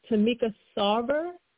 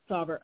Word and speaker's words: Tomica Sarver: Is it Tomica Sarver? – Sarver. Mm-hmm. – Sarver.